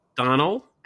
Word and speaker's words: Domhnall: Is it Domhnall? Domhnall? – No, it's Domhnall. – Donald? – Oh. Yeah.